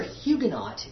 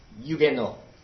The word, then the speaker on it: Huguenot: In French, it's Huguenot. And the H is silent and the T is silent.